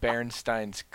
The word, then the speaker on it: Berenstain: But it was, it's not. It's Berenstain.